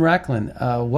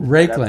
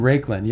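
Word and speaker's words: Raiklin: Ivan Racklin, welcome to the Scott Adams Show. – Hey, Scott, you almost got it up right. It's Ivan Racklin is how I pronounce it. – Racklin.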